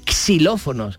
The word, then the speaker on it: xilófono: Como va al principio de la palabra, entonces se pronuncia como X. Por ejemplo, em, eh, extensión, exclusivo, xilófono. Em, no, no tenemos que decir xilófonos, decimos xilofón o xilófono, ¿no? Con S directamente.